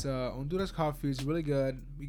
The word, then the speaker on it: Honduras: Yeah, silent H. Honduras.